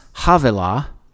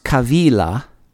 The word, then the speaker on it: Havilah: Now this river compasses the whole land of, in English, it looks like it should be pronounced Havilah, but in Hebrew, it's pronounced Kavila.